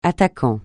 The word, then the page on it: attaquant: francais